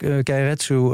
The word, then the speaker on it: Keiretsu: Daar heb je de, hoe zeg ik dat goed, de Keiretsu? – Ja, Keiretsu wil zeggen dat een bedrijf heel erg verweven is met eigenlijk haar financiers, met haar leveranciers.